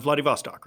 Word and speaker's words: Vladivostok: Well, I mean, talking about that, so you're originally from um, Vladivostok, right? – That's right.